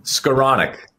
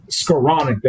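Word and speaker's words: Skowronek: Skoronovic. How do you say that, JB? I call him Skoronovic. We'll call him Ben Sko. How do we say it? – Skoronic. – Skoronic, that's great.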